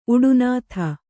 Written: ಉಡುನಾಥ

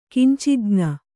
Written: ಕಿಂಚಿಜ್ಞ

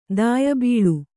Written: ದಾಯಬೀಳು

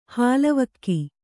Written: ಹಾಲ್ವಕ್ಕಿ

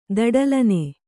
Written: ದಡಲನೆ

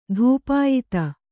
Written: ಧೂಪಾಯಿತ

